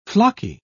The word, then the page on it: flocky: British English